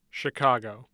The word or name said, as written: CHICAGO